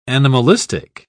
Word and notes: animalistic: British English

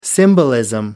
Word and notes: symbolism: British English

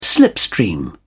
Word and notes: slipstream: British English

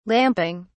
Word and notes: lamping: British English

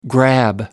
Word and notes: grab: American English

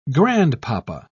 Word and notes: grandpapa: British English

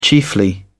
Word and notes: chiefly: British English

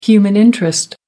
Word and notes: human interest: American English

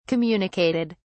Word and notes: communicated: British English

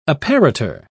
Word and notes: apparitor: British English

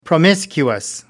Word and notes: promiscuous: American English

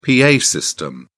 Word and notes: pa system: British English

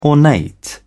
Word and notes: ornate: British English

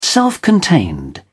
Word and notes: self-contained: British English

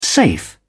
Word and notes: safe: British English

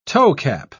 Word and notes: toecap: British English

